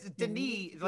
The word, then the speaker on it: Denis: Well, it's Denis, like the S is kind of silent, but yeah. – I always thought it was Denis Villeneuve.